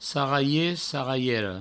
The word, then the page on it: sarralher, sarralhera: sarraie, sarraiero